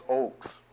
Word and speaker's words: Ochs: Ox. Oh, is that right? I might be pronouncing his name wrong. O-C-H-S. Oaks? Good, thank you. It's Oaks.